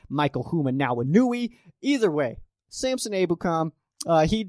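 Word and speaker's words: Ebukam: I think it was Samson Abukam, which, by the way, why can no commentator get that name right? It's Samson Abukam. Just say his name right. Abukam. Very simple name.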